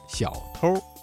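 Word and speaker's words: 小偷儿: 这句话当中啊，小偷一词的偷加了一个卷舌音日，发生了语流音变，形成了儿化音，读作小偷。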